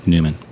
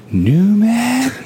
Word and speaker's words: Neumann: This is what Eric Neumann says, or Newman, I don't know how to say his name. N-E-U-M-A-N-N. Mm-hmm. How would you say it? – Newman. – Newman.